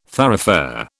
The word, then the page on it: thoroughfare: British English